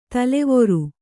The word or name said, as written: ತಲವಾರ್